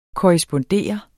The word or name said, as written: korrespondere